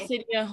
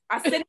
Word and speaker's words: Arsenio: Arsenio Hall, who is Semi. – Pardon? – Arsenio Hall.